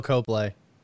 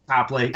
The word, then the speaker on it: Copley: It's Charlton Copley. Or Copley, whatever. Coplay. – Dude, Copley.